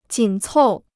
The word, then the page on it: 紧凑: jǐn còu